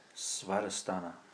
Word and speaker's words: Svadhisthana: Svadhisthana.